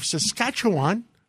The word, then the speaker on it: Saskatchewan: We have the Premier of Saskatchewan. I said it right.